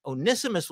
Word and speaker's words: Onesimus: Because this, so Onesimus or one Simus, that's how you spell it. Onesimus, it looks like one Simus, but and there's these names like Philemon, right?